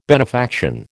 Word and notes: benefaction: American English

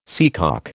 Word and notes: seacock: American English

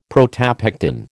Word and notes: protopectin: American English

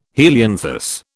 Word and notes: helianthus: British English

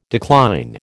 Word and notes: decline: American English

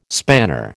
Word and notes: spanner: American English

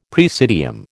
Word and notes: praesidium: American English

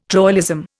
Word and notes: troilism: Indian

